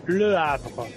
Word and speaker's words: Le Havre: Le Havre.